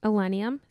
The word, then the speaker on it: Illenium: So I took the day off on Monday because we went to Illenium. Is that how you say it? – Yep.